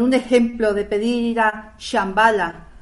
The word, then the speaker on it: Shambhala: cuando yo oigo la palabra Shambhala, yo creo, y corregirme si estoy equivocada, por favor, de que la SH es sh, no como una che.